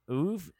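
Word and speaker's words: oeuvre: What's a King story that you think either sits as an outlier in his, how do you say that word? – Ouvre? – That's how it is.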